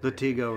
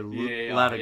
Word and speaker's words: Latigo: He goes, how do you say that? And so I'm trying to tell him how to pronounce it. – Latigo or Latigo.